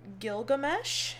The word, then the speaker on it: Gilgamesh: Gilgamesh? – Yeah.